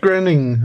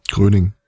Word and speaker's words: Groening: Ähm, apropos Matt Groening, äh, Groening. – Groening. Groening. Groening? Gröning. – Der lässt sich äh, ganz komisch aussprechen.